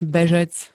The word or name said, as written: bežec